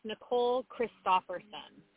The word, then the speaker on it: Christopherson: It is officially pronounced Nicole Christopherson.